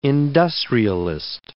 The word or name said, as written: industrialist